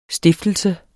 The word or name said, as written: stiftelse